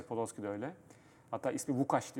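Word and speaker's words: Łukasz: Hatta işte Podolski'nin ismi L aslında Lukas değil. L'nin üstündeki çizgi Vukaş. Evet. Vukaş Podolski diye okunur.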